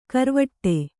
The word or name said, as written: ಗರ್ವಾಟ